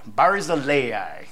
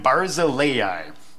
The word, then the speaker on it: Barzillai: How's that pronounced? Huh? Anybody else? It says in this one, Barzillai. Barzillai. All right. So I do still have one of those where maybe it's wrong, but it does have the pronunciations in there. So I'm going to be calling him Barzillai.